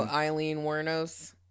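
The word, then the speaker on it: Aileen Wuornos: Man, good on you for knowing that, because I was looking at her name and was like, how do you say this? Because it's spelled like Alien Wuornos.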